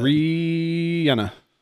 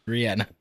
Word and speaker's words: Rihanna: Yes, Rihanna. That's how she pronounces it. – Rihanna. – Rihanna.